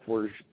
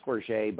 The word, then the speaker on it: Forget: My name's Derrick Forge. It's pronounced Forge, but I like Forget better. It sounds kind of more punk rock. But so we'll go with Derrick Forge. That's easier to spell. D-E-R-R-I-C-K-F-O-R-G-E-T.